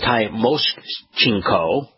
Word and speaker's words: Tymoshenko: It's Tymoshenko.